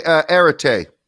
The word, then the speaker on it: Arete: He is the founder of the Omada Arete Thought Group. I probably didn't say that right, did I? – It's okay. Arete. – Arete. – Yeah. Arete. Yeah.